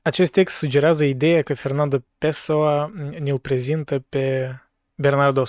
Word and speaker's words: Fernando Pessoa: Am căutat uh, oameni uh, spanioli pe YouTube care îi pronunță numele și da, e Fernando Pessoa. Ok, ne-am, uh, ne-am lămurit cu accentul.